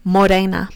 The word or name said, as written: morena